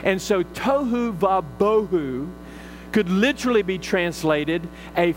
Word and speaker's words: bohu: The second word, empty, is the Hebrew word bohu, B-O-H-U, bohu, would be the way the Hebrews pronounce it, but since I'm not a Hebrew, I pronounce it bohu.